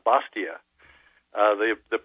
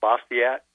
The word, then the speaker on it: Bastiat: And that's right in line with what, in fact, the first class required us to read Bastia. The professor there said it's not Bastia, it's not Bastiat, it's Bastia. B-A-S-T-I-A-T, and the T is not pronounced, but it's Bastia.